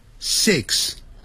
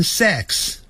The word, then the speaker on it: six: 六 six six，一定 it it 二三四 it，短的元音 six six，很多人会念成 sex， sex 是性，性别那个性，好 sex 那是 s e x，所以六啊要念成 six six。